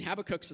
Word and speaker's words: Habakkuk: I mean, Habakkuk's a strange name. You can pronounce it multiple different ways. We won't argue about how to say it. I say Habakkuk because I'm right, but that's fine.